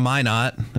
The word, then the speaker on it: Minot: Which, doesn't it make more sense though that like if you're from Minot, did I say that right? – Mm-hmm.